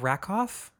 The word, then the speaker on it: Rakoff: It was David Rakoff, is that how you say it? – Oh, yeah.